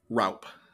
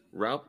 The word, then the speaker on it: Raup: Raup. – Raup? Like, hey, sorry, man. One syllable. That's all you get.